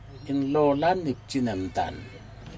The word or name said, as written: inlolan itjilondan